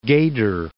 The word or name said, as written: Gadir